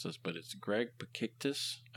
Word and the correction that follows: Pikitis: I think. Pikitis. It's Greg Pikitis.